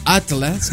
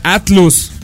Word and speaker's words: Atlus: Atlas. Pues así se pronuncia, no es Atlus. – Es Atlus.